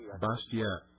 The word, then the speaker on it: Bastiat: The last name is B-A-S-T-I-A-T. – Bastiat, yes. It's pronounced Bastiat.